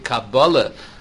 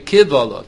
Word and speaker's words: Kabbalah: But other Rishonim say in the Shita Gubetz on the first daf in Baba Kama, you don't pronounce the divrei Tam divrei Kabbalah. The Yavin is not that it's a Kabbalah tradition from the days of Moshe Rabbeinu. Divrei Tam divrei Kivolah. Kivolah means likbol, means to complain.